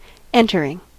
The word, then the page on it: entering: /ˈɛnt.ə(ɹ).ɪŋ/